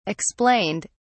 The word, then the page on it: explained: British English